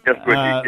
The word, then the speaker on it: Gestwicki: Gestwicki, yep.